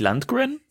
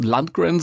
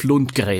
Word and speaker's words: Lundgren: Eigentlich ja nicht, weil er ist ja ein Schwede. Also deswegen wird das ja nicht englisch ausgesprochen. Man kann natürlich auch, wenn man international unterwegs ist, Lundgren sagen, aber für mich ist es Dolph Lundgren.